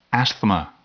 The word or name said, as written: asthma